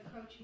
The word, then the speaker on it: Croce: In the reading, first I read Croce. Is that how you say his name? – Yes, that's right.